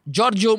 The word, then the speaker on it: Giorgio: Ela originalmente se chama To Be a Number One, a melodia do italiano Jorge, Giorgio Moroder, que eu... na época era produtor de Donna Summer. Eu gosto, eu gosto do seu italiano, da pronúncia. Giorgio Moroder.